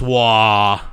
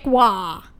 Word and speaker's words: Roy: It really irritates me because it's spelled R-O-Y. That is Roy. We are in America. Okay. Also, though, I'm just like, Marchessault, you don't pronounce a W with his R.